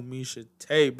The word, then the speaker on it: Misha Tate: Misha Tate, baby girl.